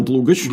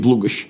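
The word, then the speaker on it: Длугош: А, так оно появилось, насколько я понимаю, в 17 веке Э, благодаря польским историкам, кажется, это Ян Длугач. Длугач, Длугач, Длугач. – Да, угу. я больше привык, кстати, произношение Длугач. Угу.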